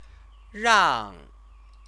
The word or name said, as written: rang